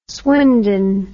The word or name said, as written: swindon